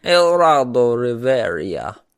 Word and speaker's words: Geraldo Rivera: In 1995, Geraldo Rivera, is that how you say his name? Geraldo Rivera? – Geraldo Rivera.